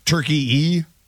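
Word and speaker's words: Türkiye: So is the country that we used to call Turkey, now pronounced Turkey-E.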